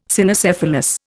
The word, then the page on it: Cynocephalus: Indian